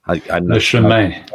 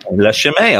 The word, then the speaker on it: Le chemin: Le chemin, right? – Le chemin. – Le chemin. Okay, thank you. That's how you say it.